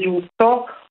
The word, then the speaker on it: giusto: In effetti hai ragione, questo olio è possibile acquistarlo su una piattaforma che si scrive G8 con due O, ma si legge giusto,